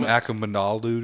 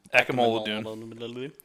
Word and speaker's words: Akamanalu: Dude, when, okay, like, I'm going to butcher his name, Freedom. – Freedom Akamanalu. – Akamanalu. That's pretty good.